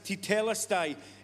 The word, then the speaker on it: tetelestai: There's all different ways of saying it. I've tried to say this about 10 times, different ways. But I'm going to say it like this: tetelestai.